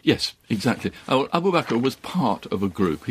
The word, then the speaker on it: Abu Bakr: And this was a great friend of Muhammad's, Abu Bakr. Is that how you pronounce it? – Abu Bakr.